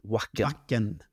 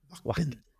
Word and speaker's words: Wacken: Jag vet inte hur de uttalar sitt Wacken. – Wacken. Jaha, det är som backen. Wacken.